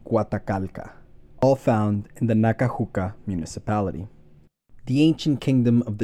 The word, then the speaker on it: Nacajuca: and Nacajuca, which I incorrectly pronounced as Nacajuac last episode.